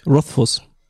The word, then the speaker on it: Rothfuss: Genau, mit U geschrieben. Rothfuss. Also wird tatsächlich mhm. Fuß ausgesprochen.